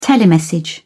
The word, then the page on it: telemessage: British English